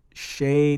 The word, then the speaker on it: Shea: And the person's name is spelled S-H-E-A, and last name Diamond. – Yeah. – Right? So she goes, Shay Diamond is next on the microphone. That's how you would say that, like Shay Stadium.